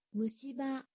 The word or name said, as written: mushiba